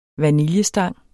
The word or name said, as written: vaniljestang